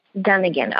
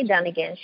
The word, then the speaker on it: Dunigan: Today we have Ebony Dunigan. Girl, did I say that right? – Yes. – Dunigan. Okay, so we have Ebony Dunigan.